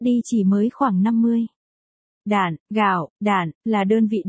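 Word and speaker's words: đản (石): Đản, là gì trước đã, từ si ở đây thời nay có nghĩa là thạch, tức đá, nhưng khi dùng làm đơn vị đo lường thì phải đọc là đản, và một đản, tương đương với 10, đầu, đu, hay xô.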